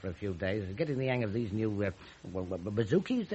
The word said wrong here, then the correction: bazookas: they started coming. – Bazookas. – Well, is that how you say it? – Yeah.